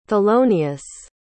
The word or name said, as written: thelonius